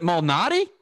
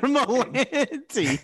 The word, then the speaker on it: Malnati: Is it Malnati? – Yes, Malnati. – Peter Malnati. – That's it. – Peter Malanti.